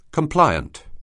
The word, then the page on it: compliant: anglais